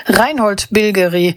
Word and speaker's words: Reinhold Bilgeri: der Titel kommt aus Österreich, Bilgeri, nämlich aus dem Jahr, wobei ich brauche den gar nicht Englisch auszusprechen, der Mann hört nämlich auf den Namen Reinhold Bilgeri, dann wollen wir ihn doch auch so aussprechen, wie er eigentlich gehört.